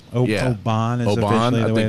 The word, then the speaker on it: Oban: I'm drinking, how do you pronounce it? Is it Oban? – Yes.